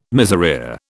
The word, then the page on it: miserere: British English